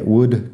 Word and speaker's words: would: porque se escriben parecidas y todos tienen la L que no suena y el mismo sonido de la U corta. Así que would, could, should.